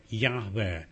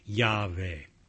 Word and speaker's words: Yahweh: And scholars have surmised that it was probably pronounced Yahweh.